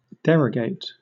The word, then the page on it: derogate: British English